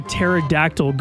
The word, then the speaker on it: pterodactyl: Why don't you ever hear a pterodactyl go into the bathroom? – Why? – A kid told me this one recently. – Oh, okay. – Because the pee is silent.